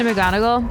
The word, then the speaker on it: McGonagall: Professor McGonagall.